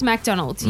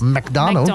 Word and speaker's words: McDonald's: McDonald's. – McDonald's. Now, I can't. How did I say it the first time? McDonald's. – It sounded like you said McDonald's, like you emphasised the Mac.